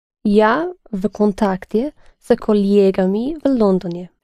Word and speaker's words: коллегами: But the stress is on this stem, not on the ending. – С коллегами. – Yes, the same as коллега. С коллегами.